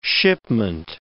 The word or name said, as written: shipment